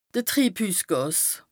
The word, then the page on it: de Triibhüssgàs: Bas Rhin